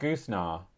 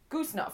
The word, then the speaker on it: Goosnargh: I would call that goosenar. – Goosenar. Goosenar, fine. – Spell it. Spell it for me. – G-O-O-S-N-A-R-G-H. Goosenar. Goosenar? – Goosenar, I would say. Or goosenar it could be.